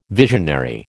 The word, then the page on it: visionary: American English